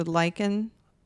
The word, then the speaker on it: Leichen: Is it L-E-I-C-H? – Mm-hmm. So lichen. – Yeah. So you pronounce the second. – And then schmaus. – S-C-H-M-A-U-S.